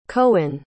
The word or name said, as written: coen